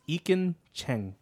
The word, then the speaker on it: Ekin Cheng: Is it Ekin? – No, it's Ekin Cheng. – Ekin Cheng. Cheng. I can't pronounce that properly. I've been pronouncing his name wrong this whole time.